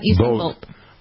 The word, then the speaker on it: Usain Bolt: Yep, Usain Bolt.